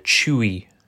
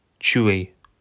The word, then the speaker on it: Chuy: Oh, for that one, the reward you actually get is uh, Chewy. Chewy I think that's how you say it. C H U Y, um, which is a operator.